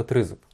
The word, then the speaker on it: тризуб: А це щось таке велике, щось чарівне, і тому вирішили назвати його «тризуб». Змістили наголос на «три», щоб це «три» головне.